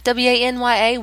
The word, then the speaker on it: Wanya: W A N Y A Wanya.